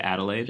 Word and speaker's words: Adelaide: Is it Adelaide? – Yeah. – Is that how you pronounce it? – Yeah.